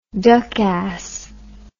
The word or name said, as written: duck ass